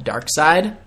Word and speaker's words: Darkseid: There's this dude out there called Darkseid. Side is spelled S-E-I-D.